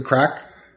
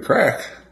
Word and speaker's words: craic: It's good crack. – Good crack. – It's Irish slang. – Oh, I didn't know. – Yeah. But it's spelled C-R-A-I-C.